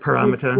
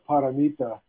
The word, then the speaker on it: paramita: Well, the word paramita? – Who knows how to say it right? I usually say it paramita.